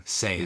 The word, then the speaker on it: Saiyan: Saiyan? How do you pronounce it? – Saying is typically how it's said.